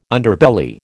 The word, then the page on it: Underbelly: American English